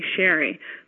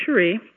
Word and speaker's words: Sherri: My name is spelled S-H-E-R-R-I, so a lot of people call me Sherry, but it's pronounced Cherie, like the French Cherie, which means dear one.